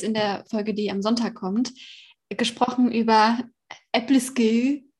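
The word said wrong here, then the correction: æbleskiver: wird das so ausgesprochen? Ebleskiwa. Ach, okay. Oh, dann habe ich es komplett falsch ausgesprochen.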